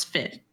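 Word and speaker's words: Figh: And Jeffrey is the book published in 1969 by author Catherine Tucker Windham and Margaret Gillis Fig. Silent H, which tells of the local legends throughout the state of different hauntings, poltergeist behaviors, and other unexplained events.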